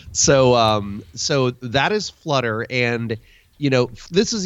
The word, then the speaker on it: Phluttr: But it's set in present-day San Francisco, and it's the tale of this really diabolical social media company called Flutter, and it's spelled P-H-L-U-T-T-R because we know how to spell in Silicon Valley, right? – Yes.